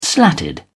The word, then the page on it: slatted: British English